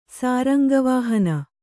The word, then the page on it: ಸಾರಂಗವಾಹನ: kannada